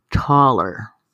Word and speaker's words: taller: And I would see this word, T-A-L-L-E-R, all over the place. Taller. What in the heck is a taller? Well, it's pronounced taller in Spanish, and it really means a shop, a place where you get things fixed.